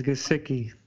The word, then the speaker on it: Gesicki: It's Gesicki.